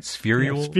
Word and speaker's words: spherules: Now, Professor Loeb just recently claimed that tiny sphericals or spherules, am I pronouncing that right? Spherules? – Yeah, spherules or spheres.